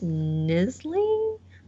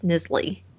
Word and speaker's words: Knisley: It's called Displacement, and it is by Laura, I believe it's pronounced Nisley. I'm not sure. Let me see if I can find the spelling real quick. K-N-I-S-L-E-Y. So I'm thinking it's Nisley.